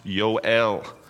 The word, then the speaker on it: Joel: There is no J sound in the entire Hebrew language. Sometimes it's an H. Most cases, it's in a Y. In the case of Joel, his name is pronounced Yoel.